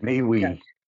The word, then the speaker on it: MeWe: I keep saying it wrong. Is it MeWe? – MeWe.